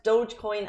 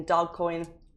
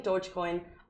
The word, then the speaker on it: Dogecoin: A Dubai real estate co- excuse me, company will accept Dogecoin as payment. And I hope I'm pronouncing that wrong. I started saying Dogcoin. It's not how you pronounce it.